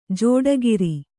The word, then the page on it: ಜೋಡಗಿರಿ: jōdagiri